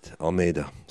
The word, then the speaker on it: Almeida: And I said his name wrong consistently last night. – Get out. Did you? – Yeah, I did. Almeida. Sorry, Almeida.